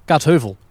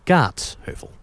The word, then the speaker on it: Kaatsheuvel: Zoals mensen ook zeggen, ik ga naar Kaatsheuvel. Nee, het is Kaatsheuvel. Als mensen zeggen, als ze het over Kaatsheuvel hebben, dan weet je, daar komen ze niet vandaan.